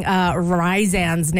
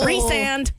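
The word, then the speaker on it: Rhysand: I'm putting Kristen in for mispronouncing Rizan's name from Akatar. – Oh. – Rizand.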